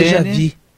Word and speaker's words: déjà vu: Pedro vai te dizer que a pronúncia correta isso é déjà vu. – Ah, desculpa. – Déjà vu. – Desculpa. Déjà vu. – É igual o Meni. – É déjà vu. Pronúncia certíssima isso aí, entendeu? – Eu aportuguesei por conta e risco.